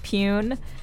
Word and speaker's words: 3PUEN: We finished out the podcast with Pune, a band whose name is spelled with a silent three at the beginning, 3-P-U-E-N, with their song Sugar Cookie, and that was performed here live at WFMU on Choking on Cufflinks a few years back.